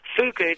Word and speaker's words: fugu: Fugu? – Fugu, that's right.